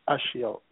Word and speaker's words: Ashil: Hi, this is Ashiel. How are you? – Hi. Could you say your name again? Sounds like an awesome one. I want to make sure. – Ashiel, A-S-H-I-L.